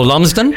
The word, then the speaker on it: Lumsden: and I always mispronounce this one too, Lumsted Beach. – Oh, Lumsden? – And around.